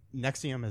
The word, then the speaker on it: NXIVM: The Nexium is NXIVM is how it's spelled.